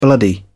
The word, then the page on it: bloody: British English